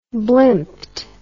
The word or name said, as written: blimped